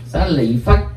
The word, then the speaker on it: xá lợi phất: Thì giống như chữ cái chữ xá lợi phất có chỗ âm là xá lị. Xá lị phất hoặc là niết bàn có chỗ đọc là nê hoàng.